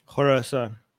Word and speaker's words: Khorasan: How do you pronounce it the right way? – Khorasan.